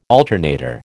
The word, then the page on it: alternator: American English